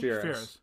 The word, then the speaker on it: Spheeris: Spheeris.